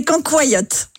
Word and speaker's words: cancoyote: Oui, comment vous dites, vous avez raison dans le Doubs, comment vous dites? – C'est cancoyote. – Cancoyote.